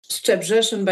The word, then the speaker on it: Szczebrzeszyn: Szczebrzeszyn by Stephen Schwartz sounds very good.